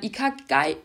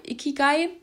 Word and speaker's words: Ikigai: Und das ist dann ein Ikigai. Ikigai sorry, ich habe es falsch ausgesprochen.